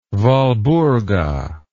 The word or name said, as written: walburga